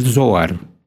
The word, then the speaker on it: Zohar: Es el Zohar. Dije la Z con Zo, pero también en hebreo también es, ¿no? – Así es.